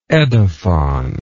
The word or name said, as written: edaphon